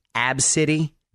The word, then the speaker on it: Abcde: The name of this five year old little girl, by the way, is Abcity, but it's spelled A B C D E.